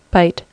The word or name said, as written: bite